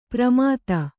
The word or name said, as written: ಪ್ರಮಾತ